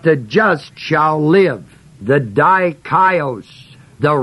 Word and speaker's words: dikaios: The word just is the Greek word spelled D-I-K-A-I-O-S. Dikaios, it's an adjective form. Its basic root form is the word dike, spelled D-I-K-E, long E.